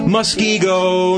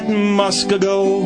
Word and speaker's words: Muskego: Muskego, not Muskego, and Shawamagon Nicolet.